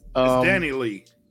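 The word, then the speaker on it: Dani Leigh: She is feeding because the mother of his child is a R&B singer by the name of Dani Lee or Dani Lay? How does she pronounce it? I don't know. – It's Dani Lee.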